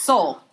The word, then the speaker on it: Seoul: It's Seoul. – Is it Seoul? – It's okay. Seoul. – The E throws me off. – I know, it's Seoul.